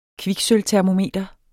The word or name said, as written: kviksølvtermometer